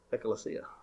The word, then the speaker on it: Ecclesia: Hey, I pronounced it right today. Ecclesia.